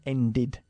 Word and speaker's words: ended: Por cierto, como end acaba en de, para formar el pasado, hay que pronunciar la E. Mm-hmm. End, ended.